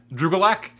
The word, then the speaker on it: Drew Gulak: And my favorite thing about Drew Gulak has to be the fact that if you say his name fast, it sounds like one word, Drew Gulak, much like Kubiak from Parker Lewis Can't Lose.